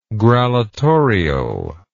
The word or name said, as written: grallatorial